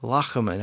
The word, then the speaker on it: Layamon: His name was Layamon, probably pronounced more like Lachaman at the time, based on the way he spelled his name and nothing is really known about him other than what he tells us in the opening lines of the text.